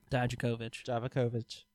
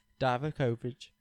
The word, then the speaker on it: Dijakovic: If I were to put Four Horsemen together right now, AJ Styles, okay, Donovan Dovjak or whatever. I can't. – Dijakovic. – You even told me that. I still said it wrong. Dijakovic.